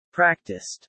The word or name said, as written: practiced